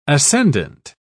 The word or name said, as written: ascendant